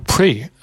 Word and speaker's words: Prix: 我说我给他拼出来了，我说拼出来以后我说你怎么发，他就说哦不对，我教你，这应该是发成 pre，后面的 x 不发音，这是从法文来的。